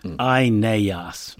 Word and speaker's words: Aeneas: His name in Latin, and indeed in Greek, consists of three long syllables. Aeneas. So that's his name, Aeneas.